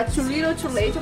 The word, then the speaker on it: Too Little Too Late: É, a original é a Too Little Too Late, eu pronunciei certo? – Corretíssimo. Teacher.